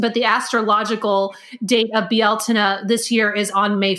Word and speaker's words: Bealtaine: And so we're right in the tides of what Americans call Beltane. The correct pronunciation is Bealtaine.